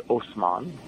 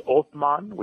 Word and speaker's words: Osman: The original member of the dynasty was a guy named Osman. And it's an Arabic name. And originally in Arabic, it's Othman, which is with a TH sound. But Turkish people can't pronounce TH, so they say S instead.